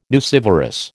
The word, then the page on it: nucivorous: American English